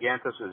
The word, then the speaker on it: Ghantous: It sounds like R-O-D, but the name is spelled R-A-A-D, and Gantus is G-H-A-N-T-O-U-S.